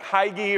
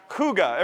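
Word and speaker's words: hygge: It looks like Hygi or Higgy or something, but it's actually pronounced huga.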